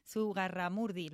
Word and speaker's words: Zugarramurdi: Zugarramurdi, ¿lo has dicho bien? – Zurragamurdi, lo he dicho bien.